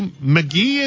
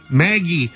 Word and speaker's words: Magie: Second item, 1909 to 11, T206 Sherry McGee, as it's pronounced? Sherry McGee? – Maggie. – And this is the M-A-G-I-E error graded PSA 2. So his name, I think, should have been spelled M-A-G-E-E. And this was the error M-A-G-I-E.